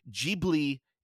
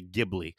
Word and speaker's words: Ghibli: I believe, because I tried to look this up before we started the review, and I think Ghibli is how Hayao Miyazaki pronounces it, so I think that, but maybe that's the Japanese pronunciation, and then I've heard a lot of people in the West will say Ghibli, so I think both are right, because Miyazaki says that the name was a random thing he picked from, like, a plane that he loved.